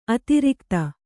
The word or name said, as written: ಅತಿರಾಗತೆ